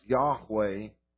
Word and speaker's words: Yahweh: which is pronounced Yahweh in English,